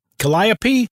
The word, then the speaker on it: Calliope: C-A-L-L-I-O-P-E. Calliope? – Calliope. – Sure.